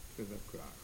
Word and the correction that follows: Trivakra: But isn't it, it's a short A in the middle one, right? Trivakra, right?